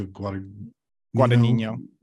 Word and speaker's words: Guadagnino: Luca Guadagnino.